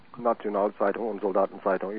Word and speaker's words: Nationalzeitung und Soldatenzeitung: In fact, that information appeared in you'll have to correct me on the pronunciation, the Deutsche Nationalzeitung and Soldatenzeitung. – Nationalzeitung und Soldatenzeitung. You're very close there.